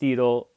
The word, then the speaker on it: Wedel: Apparently, it's pronounced Edel, though it is spelled Wedel, again with that W leading off.